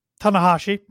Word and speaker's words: Tanahashi: We've got John Moxley coming out to have a face-to-face, and I'm going to butcher his name, so help me out here. Hiroshi Tanahashi? – Tanahashi, pretty close.